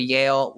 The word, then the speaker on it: Yehl: You know, you can follow me on Twitter, which is at Joshua Yale.